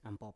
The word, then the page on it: ampop: Biak